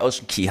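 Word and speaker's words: Ocean Cay: Ja, auf den Bahamas wird K wie Key ausgesprochen und deswegen disziplinieren wir uns nun schon sehr, sehr lange, dass wir halt eben sagen Ocean Key. Klingt auch viel schöner als Ocean K, muss man ja auch nochmal dazu sagen.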